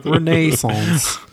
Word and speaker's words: Renaissance: Oh, that's the British pronunciation. Renaissance.